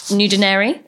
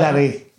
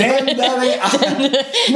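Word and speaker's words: ndunderi: Now, one of the specialities I read, which this might be completely wrong, so you can correct me, is it called nudinari? Is that right? Am I butchering the pronunciation? It's a type of gnocchi made with flour. – Dundari. – There we go. Completely butchered. – Dundari. No, it's all right. Dundari. – With a silent N at the front.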